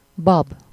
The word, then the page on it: bab: /ˈbɒb/